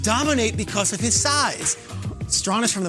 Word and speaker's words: Strachan: Now, his name is spelt Strachan, like the Hall of Famer Strahan with a C, but it's pronounced Strahan.